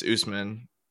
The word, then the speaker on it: Usman: Like Osiman, like, yeah, I know. I just, but it's Usman.